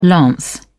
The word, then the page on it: lance: British English